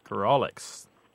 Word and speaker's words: grawlix: Well, the best word I know for this is grolix. – Grolix. – How do you spell that? – You spell it G-R-A-W-L-I-X, Lindsay.